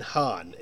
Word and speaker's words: han: Okay, Sweden, this is going to be an awful pronunciation, but Swedish has two terms with she and he. Hon and Han, H-O-N-H-A-N.